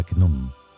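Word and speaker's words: Khnum: Hasta aquí el capítulo dedicado a Cnum. Claro, cuesta decirlo porque Knum se escribe con. empieza con K, entonces luego N-U-M.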